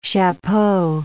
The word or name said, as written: chapeau